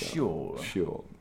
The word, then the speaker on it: Síol: S-I-O-L. – S-I-O-L Studios. – Yeah, pronounced Síol.